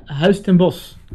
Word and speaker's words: Huis Ten Bosch: Uh, Huis Ten Bosch.